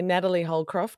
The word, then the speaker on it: Natalie Holcroft: Nat, I'll say Natalie Holcroft because that's your full name.